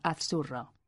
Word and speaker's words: azzurro: Pazo se pronuncia pazzo pero azurro, A-Z-Z-U-R-R-O, se pronuncia azurro.